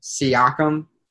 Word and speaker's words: Siakam: Am I not pronouncing it? Siakam? – Siakam. There you go.